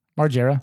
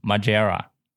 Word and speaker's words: Margera: Margera 好了，你们记住了吗？Bam Margera。